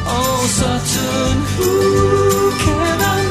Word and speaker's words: Sutton Hoo: Now, going to the last song, is it Sutton Hoo? Is that how you say it? – Yes, it is, yeah.